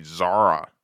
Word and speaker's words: Xsara: It's spelled X-S-A-R-A, Zara. I would say Zara. That makes sense to me.